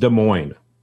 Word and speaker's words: Des Moines: Is Des Moines or is it just Des Moines? – Just Des Moines. – Okay. – It's not plural.